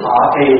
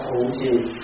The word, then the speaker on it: thọ trì: Ví dụ người Bắc chữ thọ trì thì người Bắc đọc là thụ trì.